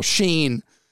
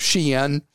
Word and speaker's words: Shein: And I've been asked an amazing number of times why we never talk about what Americans call Shein, but I think the proper way to pronounce it is Shein.